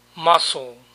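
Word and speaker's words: mussel: Mejillón, o también en algunos lugares lo llaman choro, muscle. Músculo y mejillón se pronuncian igual.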